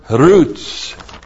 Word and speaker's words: rutz: This is the preposition kaph, prefixed to the masculine plural adjective givor, plus the kal imperfect of the verb rutz. Got to be able to roll your R when you say that.